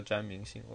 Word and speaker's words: 詹明信: 看这个标准发音叫詹姆逊，他八十年代的时候叫詹明信，我我我我教惯了，都可以啊，无所谓。